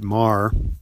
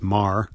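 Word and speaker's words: Meagher: This is a book from a few years ago by Robert Marr, spelled out meager, but pronounced Marr in good Irish pronunciation, probably addresses this the best, that certain kinds of wars create more moral injury.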